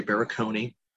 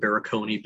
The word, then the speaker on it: Barakony: And on LinkedIn, it would just be under my name, SJ Barakony. Since my name is that unique, SJ being my name, not initials, and Barakony being a four-syllable phonetic Hungarian last name, easy to find me.